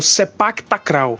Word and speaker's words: Sepak Takraw: que é o Sepak Takraw. É, se escreve S-E-P-A-K, espaço T-A-K. K-R-A-W, Sepak Takraw.